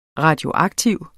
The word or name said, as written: radioaktiv